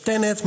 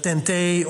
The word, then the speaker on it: TEN-T: En dat kun je uitspreken als TENET, maar ook als TEN-T of TEN-T.